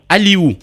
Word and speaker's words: Aliou: Aliou, mon nom s'écrit, moi, ça, c'est mon prénom.